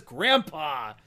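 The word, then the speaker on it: Grampá: I have literally listened to an interview with Frank Miller. He says Grandpa. I'm sure it's Grandpa.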